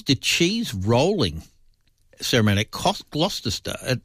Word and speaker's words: Gloucester: Yeah, it's pronounced Gloucester.